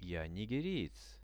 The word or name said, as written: ja nigeriec